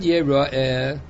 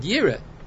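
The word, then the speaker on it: Yeroe (יראה): So the word Yeroe, that's the kri. You pronounce it Yeroe. The way it's written, one could have read it Yira.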